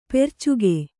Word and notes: ಪೆರ್ಚುಗೆ: percuge

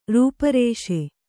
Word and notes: ರೂಪರೇಷೆ: Kannada

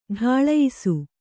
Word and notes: ಢಾಳಯಿಸು: Kannada

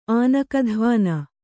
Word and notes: ಆನಕಧ್ವಾನ: Kannada

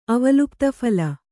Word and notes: ಅವಲುಪ್ತಫಲ: Kannada

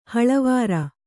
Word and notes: ಹಳವಾರ: haḷavāra

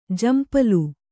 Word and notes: ಝಂಪಳ: jhampaḷa

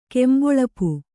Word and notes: ಕೆಂಬೊಳಪು: kannada

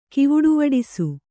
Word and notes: ಕಿವುಡುವಡಿಸು: Kannada

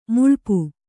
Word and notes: ಮೈಲಾಪ: mailāpa

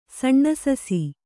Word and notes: ಸಣ್ಣಸಸಿ: kannada

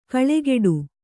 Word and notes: ಕಳೆಗೊಡು: kaḷegoḍu

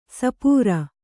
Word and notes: ಸಪೂರ: Kannada

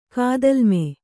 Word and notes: ಕಾದಲ್ಮೆ: kādalme

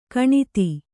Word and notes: ಗಣಿತ: gaṇita

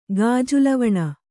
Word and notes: ಗಾಜುಲವಣ: gāju lavaṇa

